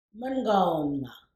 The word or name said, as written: Mengawoonnga